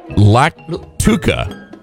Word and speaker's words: lactuca: Lactuca. L-A-C-T-U-C-A.